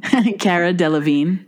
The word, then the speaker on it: Delevingne: Cara Delevingne.